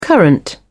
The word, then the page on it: current: British English